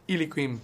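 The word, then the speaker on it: Eliaquim: Elokim Mangala, which I think is what we said is how you pronounce it. – Eliquim, I think it is. Something like that. – Eliquim. – That's the one.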